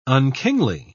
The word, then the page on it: unkingly: British English